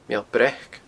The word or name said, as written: Meall Breac